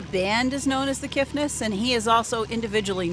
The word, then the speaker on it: Kiffness: Did I say that right? The Kiffness? – The Kiffness. There's okay. No T.